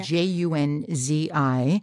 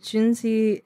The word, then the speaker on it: Junzi: yeah, I, I, always tell people it's pronounced like Jay Z, but like Junzi. um, Junzi, uh, or in Chinese, Junzi, first tone and third tone.